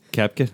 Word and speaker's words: Koepka: Say it for me. – Koepka. – Koepka. – Just get rid of the O.